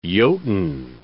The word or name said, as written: Jotunn